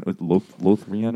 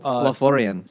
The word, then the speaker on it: Lothlorien: The book, yeah, but like the Galadriel scene, like they're in Lothrien or whatever it's called. – Lothlorien.